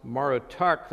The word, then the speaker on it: Marduk: While his name is Marduk, it's probably pronounced Marutuk, that shows associated with Utu, the sun god in Sumerian mythology.